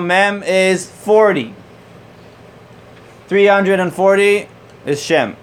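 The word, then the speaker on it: Shem (שם): Shem. – Shem.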